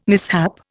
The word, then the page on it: mishap: Indian